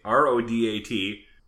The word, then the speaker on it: Rodat: The writer, Robert Rodat, Rodat, R O D A T, pronunciation guide, no, Rodat, I uh, read the book D Day, June 6, 1944, The Climactic Battle of World War II by historian Stephen Ambrose.